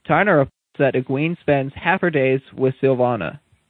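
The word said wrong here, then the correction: Silviana: It's Sylviana or Sylvana? – Sylviana. – Yeah. Then I've been saying it wrong, too bad.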